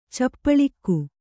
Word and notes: ಚೌಪಳಿಕೆ: caupaḷike

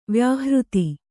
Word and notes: ವ್ಯಾಹೃತಿ: vyāhřti

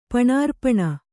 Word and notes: ಪಣಾರ್ಪಣ: panārpaṇa

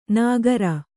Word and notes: ನಾಗರ: nāgara

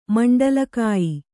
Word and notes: ಮಂಡಲಕಾಯಿ: Kannada

